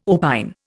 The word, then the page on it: opine: Indian